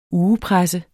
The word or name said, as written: ugepresse